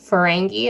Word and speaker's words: Ferengi: Okay, so basically they came up on the Ferengi. Am I saying that right? Yep.